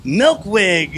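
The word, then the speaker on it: Melkweg: I'm with the DJ Sessions coming to you live from Amsterdam, ADE at Milkwig. Did I say it right? – You got it.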